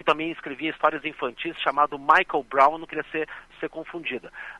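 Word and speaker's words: Miquel: A grafia é estranha, M de Maria, I-Q-U-E-L, mas a pronúncia literalmente é Michael, Michael Brown.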